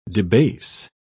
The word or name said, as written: debase